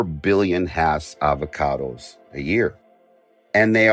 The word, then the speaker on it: Hass: And it is pronounced Hass. A lot of people say Hass. Other people say Hass. There's a debate. I got a phone call from Rudolf Hass's grandson telling me specifically how to pronounce his name. It's Hass.